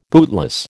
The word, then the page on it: bootless: American English